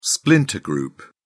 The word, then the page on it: splinter group: British English